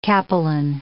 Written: capelin

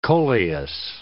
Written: coleus

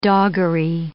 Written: doggery

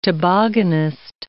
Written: tobogganist

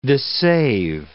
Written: dissave

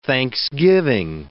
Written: thanksgiving

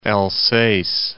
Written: Alsace

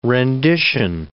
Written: rendition

